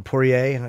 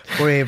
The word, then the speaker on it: Poirier: Poirier. I always fuck up the French. Whenever I don't pronounce the R, I'm supposed to. Poirier versus Johnson on Saturday on Fox Sports 1.